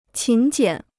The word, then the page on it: 勤俭: qín jiǎn